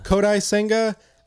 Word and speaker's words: Kodai Senga: Kodai Senga.